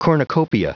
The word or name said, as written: cornucopia